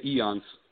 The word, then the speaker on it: Eons: Now pronounce it for me because I like screw up names big time. – Yep. We say Eons.